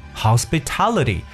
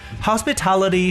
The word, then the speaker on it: hospitality: hospitality，各位在读音的时候特别注意一下这个单词的重音部分。 hospitality. Hospitality 是 hospitable 的名词形式，it means friendly and generous behavior towards guests，所以它就表示为殷勤或者好客。